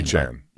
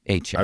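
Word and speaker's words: Achane: A-Chain. – A-Chain. – I think I looked at it up because I was curious. I've heard multiple, but Chan, A-Chain. – Those are two serious weapons, and A-Chain can take it distance every time he touches the ball.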